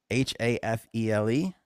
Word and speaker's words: Hafele: H A F E L E Hafele.